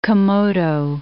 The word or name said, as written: Komodo